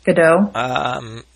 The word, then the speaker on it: Gadot: Gal Gadot? Godot? – Godot.